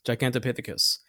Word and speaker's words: Gigantopithecus: Gigantopithecus.